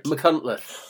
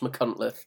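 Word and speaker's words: Machynlleth: McCuntleth. – The McCuntleth Festival. – It's absolutely not spelt like it's spoken because it's in Wales, but it's definitely pronounced McCuntleth.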